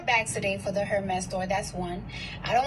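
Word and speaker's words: Hermès: And there was also talking the H is silent. – Was also saying how Hermes.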